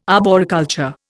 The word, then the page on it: arboriculture: Indian